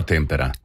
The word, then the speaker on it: Tempera: Siamo sicuri che si pronuncia Tempera, Sì. – Magari non che sia Tempera, perché non credo. – No, Tempera. – Tempera, come il Tempera Matita.